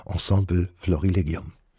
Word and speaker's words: Ensemble Florilegium: Und das hören Sie jetzt komplett mit dem britischen Ensemble Florilegium. Es wird tatsächlich auch im Englischen so ausgesprochen, Ensemble Florilegium.